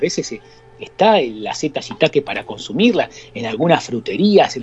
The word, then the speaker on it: shiitake: Es el famoso shiitake, el shiitake, ¿eh? que muchos de nosotros conocemos. Se escribe S-H-I-I-T-A-K-E, el shiitake.